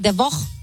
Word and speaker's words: Devaux: Cherie DeVoe. Is that how you say it? DeVoe. D-E-V-A-U-X. – That sounds bougie. How do you say it? – D-E-V-A-U-X. – I'm not going to answer you. How do you spell it? – D-E-V-A-U-X. Cherie DeVoe. – DeVoe. I think I heard DeVoe. – DeVoe. So congratulations. – As in Belle Biv DeVoe.